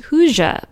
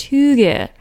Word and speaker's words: hygge: But it's the concept that I mistakenly thought was pronounced Hygge, but it's actually pronounced Hygge.